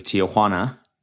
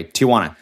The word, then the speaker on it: Tijuana: Like I pronounce it. I think I pronounce it correctly there, like a Mexican would. Tijuana. It seems to be how they, you know what? Fuck it. I'll just say it the white way.